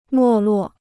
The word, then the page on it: 没落: mò luò